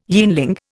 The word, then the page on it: yeanling: Indian